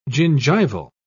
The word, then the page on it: gingival: British English